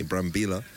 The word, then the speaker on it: Brambilla: Brambilla is due a Pedler de Charme t-shirt.